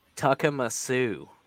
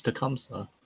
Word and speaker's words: Tecumseh: Well, how else do you pronounce Tecumseh? – It's probably Tuckamasoo. – Nah, it's like Tecumseh. It's like Tecumseh. – Nope, it's Tecumseh.